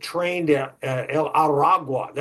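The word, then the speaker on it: Tren de Aragua: Venezuela has—they've got this new gang that we recently has come to our attention, called the Train del Arauto. I think that's the way you pronounce it. Train del Aragua. That's what it is.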